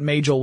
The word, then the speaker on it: Majel: And the project name at Google was Majel, or Majel, depending upon how you want to pronounce it. But Majel would be the way her name was actually pronounced.